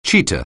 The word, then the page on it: cheetah: anglais